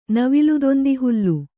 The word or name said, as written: ನವಿಲುದೊಂದಿಹುಲ್ಲು